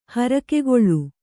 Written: ಹರಕೆಗೊಳ್

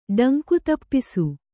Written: ಡಂಕುತಪ್ಪಿಸು

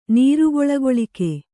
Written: ನೀರುಗೊಳಗೊಳಿಕೆ

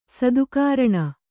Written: ಸತ್ಕರಣೆ

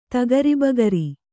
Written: ತಗರಿಬಗರಿ

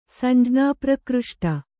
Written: ಸಂಜ್ಞಾಪ್ರಕೃಷ್ಟ

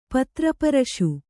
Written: ಪತ್ರಪರಶು

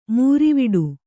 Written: ಮೂರಿವಿಡು

